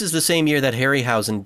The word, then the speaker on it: Harryhausen: It wasn't all the, how do you say his name? Ray Harryhausen? Is that how you pronounce it? – Yeah, Ray Harryhausen, yeah.